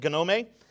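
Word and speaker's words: gnome (γνώμη): And the Greek word for judgment is gnome. It looks like gnome, but it's pronounced gnome.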